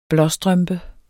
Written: blåstrømpe

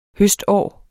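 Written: HØSTÅR

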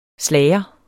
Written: SLAGERNE

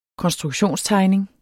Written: konstruktionstegning

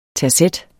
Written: terzet

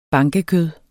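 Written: BANKEKØD

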